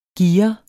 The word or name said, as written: gear